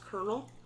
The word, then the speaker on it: colonel: The worst is that it took me so long to learn that colonel was actually pronounced colonel.